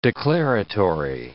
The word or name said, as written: declaratory